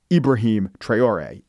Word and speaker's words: Ibrahim Traoré: And the reason I said Ibrahim Traoré is I did research.